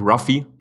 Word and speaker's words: Ruffy: Wird der Luffy Luffy ausgesprochen? – Man spricht ihn in Deutschland eigentlich Ruffy. – Ruffy. Ah, Ruffy, okay. – Ja, genau, weil der Japaner ja das L eigentlich als R spricht.